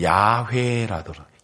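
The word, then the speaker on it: 야훼라더라 (יהוה): 그래서 여호와라고 이야기를 하는데 이제 우리는 우리 한국식 발음은 여호와고 히브리식 발음으로는 야훼라더라.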